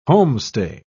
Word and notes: homestay: British English

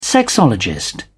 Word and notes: sexologist: British English

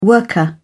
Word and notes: worker: British English

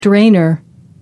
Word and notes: drainer: American English

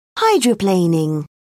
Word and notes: hydroplaning: British English

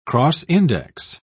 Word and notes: cross-index: British English

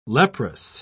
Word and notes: leprous: British English

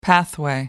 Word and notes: pathway: American English

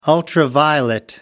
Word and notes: ultraviolet: American English

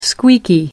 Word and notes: squeaky: American English

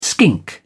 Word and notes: skink: British English

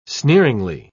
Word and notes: sneeringly: British English

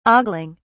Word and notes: ogling: British English

